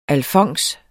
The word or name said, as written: alfons